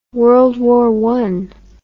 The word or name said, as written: World War I